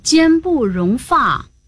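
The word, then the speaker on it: 间不容发: 哦，只要是指向毛发都读发，这样我就明白了，得读作坚不容发。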